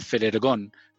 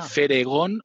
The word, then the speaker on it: Ferhergón: Entonces, Fernando, Er, de Herrera, Gon, González, Ferergón. Fer, Ergon. Quite fácil, esta, es fácil. Aunque ahora que me doy cuenta soy un poco tramposo porque realmente no es la primera sílaba de mi, de mi primer apellido. Puse ahí una R para que sonara más como sabio.